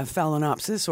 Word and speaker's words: phalaenopsis: It's just a, can't pronounce it, phalaenopsis. – Yeah, phalaenopsis or moth orchid.